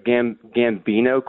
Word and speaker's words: Gambino: He was a prominent figure in the, am I pronouncing this right, Gambino crime family? – Wow, yes.